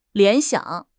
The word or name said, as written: 联想